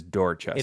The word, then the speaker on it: Dorchester: So we were wondering about Dorchester. – Yeah. – It is Dorchester. – It is Dorchester, yeah.